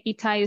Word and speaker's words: Etai: And we should say Etai is spelled E-T-A-I.